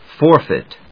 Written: forfeit

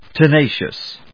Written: tenacious

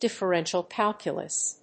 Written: Differential calculus

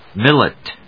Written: millet

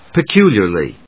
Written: peculiarly

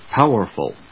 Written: Powerful

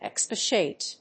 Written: expatiate

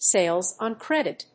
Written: sales on credit

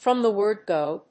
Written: from the word go

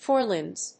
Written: forelimbs

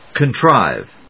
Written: contrive

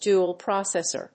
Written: dual-processor

